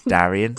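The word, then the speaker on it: Daeron: Darren. It's Darian. – Darren. – Darren. Darian. Okay, Darian is better. The way I should pronounce it.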